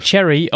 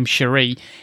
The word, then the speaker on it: Cherie: Cherry, or how she pronounced her name, Cherie, is like just above 4 foot tall, and she's got a beard that goes down to her knees.